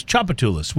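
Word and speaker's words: Tchoupitoulas: Chopatulus, which is spelled T-C-H. I won't even try to spell the whole word, but it's not chop with a C-H-O-P.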